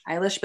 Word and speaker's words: Eilish: Eilish, Billie Eilish. – They say it's like eyelash, but with a lish.